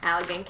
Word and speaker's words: Allegan: Allegan County.